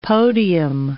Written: podium

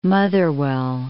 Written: Motherwell

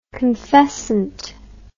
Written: confessant

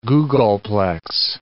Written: googolplex

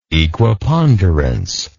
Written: equiponderance

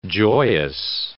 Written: joyous

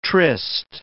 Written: tryst

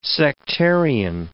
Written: sectarian

